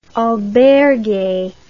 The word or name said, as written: albergue